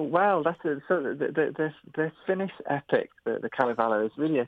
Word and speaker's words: Kalevala: the Kalevala? – Yeah.